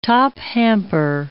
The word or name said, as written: top-hamper